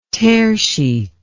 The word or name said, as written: tearsheet